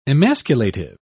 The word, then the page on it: emasculative: British English